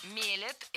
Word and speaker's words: мелят: Уже много лет у нас в стране продаются вот эти бытовые кофемашины, которые сами мелят, делают эспрессо. – Мелят – это неверно. Правильно, мелят, мелит, мелим, мелиш. Ударение в этом слове всегда стоит за корнем, а корень остается безударным.